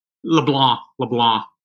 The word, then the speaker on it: LeBlanc: The Cajuns don't pronounce the C, but most other people do, so it's just fine, Toby. – So it's pronounced LeBlanc? – LeBlanc. Yeah, you just pretend you're a Frenchman.